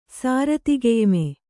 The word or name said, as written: ಸಾರತಿಗೆಯ್ಮೆ